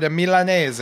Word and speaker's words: Milanese: And our special guest for the panel on the Weekly Grill is Alfred Milanese. Am I pronouncing that correctly? – Right, Milanese.